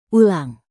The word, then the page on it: ulang: indonesian